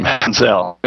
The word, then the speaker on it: Manziel: Manziel.